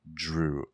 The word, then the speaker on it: Jrue: Is, Is it, it still pronounced like a Drew? – Drew, right? Like True. – It's still with a J. Drew.